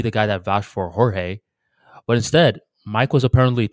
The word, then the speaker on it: Jorge: For, yeah, like, but it's spelled like J-O-R-G-E. J is pronounced ha. – Jorge.